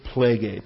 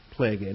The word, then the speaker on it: plege: So the noun is plegae, P-L-E-G-E. They're both the long E's, the eight's, plegae.